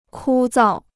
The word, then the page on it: 枯燥: kū zào